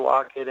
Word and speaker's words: Wocket: So Wocket sounded good, and it rhymes with lock it with your Wocket in your pocket.